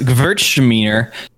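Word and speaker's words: Gewürztraminer: It's pronounced Gewurztraminer. That rhymes with Hertzmowiener.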